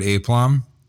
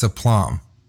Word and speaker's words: aplomb: And he pointed to the word aplomb. Mm-hmm. And I said, aplomb. And he goes, seriously? And I was like, yeah. And he goes, it's aplomb. It's pronounced aplomb. You really thought it was aplomb?